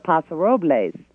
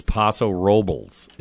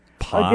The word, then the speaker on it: Paso Robles: And then I've got a beautiful Zinfandel out of Paso Robles. – No, you didn't say it right. – What's that? – It's Paso Robles is the way they say it. – Paso Robles.